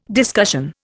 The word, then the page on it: discussion: Indian